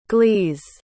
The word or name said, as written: glees